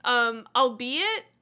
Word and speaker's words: albeit: Someone corrected me. It was, albeit, A-L-B-E-I-T.